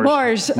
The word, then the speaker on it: morge: Do we actually call it a morgue solution? Morgue? – Morge.